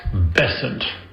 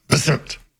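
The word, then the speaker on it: Bessent: It's still pronounced Besant. And what the heck is a tubophore? – Besant.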